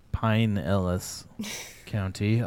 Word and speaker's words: Pinellas: Well, I learned that Pinellas County is pronounced Pinellas County.